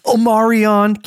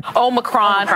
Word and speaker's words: Omicron: Omarion. Can't even say it right. – Omicron.